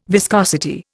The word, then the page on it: viscosity: Indian